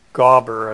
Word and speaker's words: Geber: I think it just means man God, and the man part of it, the Gabe, Geber, is from a Hebrew word meaning Geber or Gaber, as it would be pronounced.